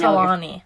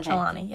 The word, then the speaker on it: Trelawney: Trelawney. – Trelawney. – Lawney. – Like, Trelawney. Trelawney. Okay. – Trelawney, yes.